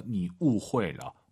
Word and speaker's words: 你误会了: 후이하지 마시고 UI 사이에 넣어서 니 우회러, 우회러라고 예쁘게 발음하시면 좋을 것 같습니다.